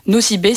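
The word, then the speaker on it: Nosy Be: Nocibé s'écrit N-O-S-Y espace B-E.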